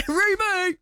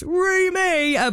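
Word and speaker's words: REME: You do have to make sure you say it right. It is Remy!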